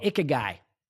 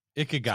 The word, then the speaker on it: Ikigai: It's called Ikigai. – Ikigai.